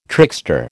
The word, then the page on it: trickster: American English